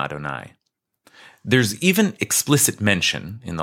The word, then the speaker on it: Adonai: Finally, here in Exodus, through Moshe, we have been given the Tetragrammaton, the famous four-letter name of God, traditionally pronounced Adonai.